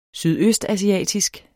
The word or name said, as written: sydøstasiatisk